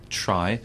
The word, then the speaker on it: Tri: Tri. No, it's Tri.